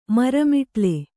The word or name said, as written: ಮರಮಿಟಿಲ